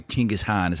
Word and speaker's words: Genghis Khan: Genghis Khan, by the way, Genghis Khan is how they say it there.